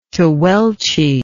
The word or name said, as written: tehuelche